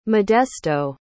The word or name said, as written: modesto